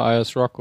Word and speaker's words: Ayers Rock: Ähm, eine Sache ist natürlich äh, spannend, wenn man schon durch die Wüste Australiens fährt, der, der ähm, Ayers Rock, oder ich weiß nicht, ob ich das jetzt richtig ausspreche. – Mm, ja, Ayers Rock